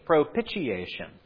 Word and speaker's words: propitiation: And in one of the sermons I listened to this week, the preacher was calling it propitiation. Pitch. Propitiation. That's not how you say the word. It's propitiation. It rhymes with initiation.